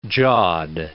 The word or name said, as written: jawed